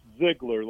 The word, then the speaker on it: Ziegler: And it's Ziegler, like Zig Ziegler.